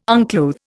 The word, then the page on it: unclothe: Indian